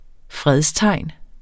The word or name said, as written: fredstegn